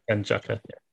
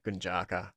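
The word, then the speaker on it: Gunjaca: Yeah, Gunjaka. – Yeah, boy. Well, we interviewed Claudia Gunjaka, and she said that we got the pronunciation right.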